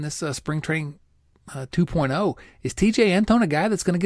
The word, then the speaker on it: TeJay: It should be Tehe, maybe. But because it's T-E-J-A-Y. I love the fact that it's TJ, but it's spelled T-E-J-A-Y.